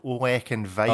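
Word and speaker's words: Ollech & Wajs: This is a brand called Oleck and Weiss. – Oh, yes. – Is spelt OLLECH and WAJS.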